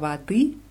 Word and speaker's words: воды: Но bei воды ist die Betonung auf I. O Анны много Wadi и сока.